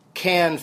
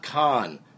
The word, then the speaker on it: Cannes: And because everyone they talk to in the movie, Scorsese, Coppola, Polanski, Gosling, they all pronounce it Cannes Film Festival, not Con. So now I can finally just pronounce it Cannes and not feel like I might be saying it wrong.